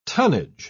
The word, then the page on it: tunnage: British English